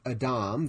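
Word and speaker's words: Adam (אָדָם): You see, because the man, which by the way in Hebrew is pronounced Adam,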